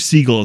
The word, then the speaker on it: Siegel: It's Kate, I believe Siegel is how she pronounces it. – Okay. – S-I-E-G-E-L, I think.